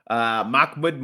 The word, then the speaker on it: Makhmud: Makhamed Muradov versus Borrello. Was I close at all at that? – Makhmud Muradov versus, I don't know how to pronounce that first name.